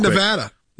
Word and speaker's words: Nevada: All right, Nevada. – Nevada, you said it right.